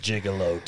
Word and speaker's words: Juggalo: It's pronounced Juggalo to you.